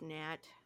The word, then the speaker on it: gnat: That actually means gnat, you know, because the G is silent.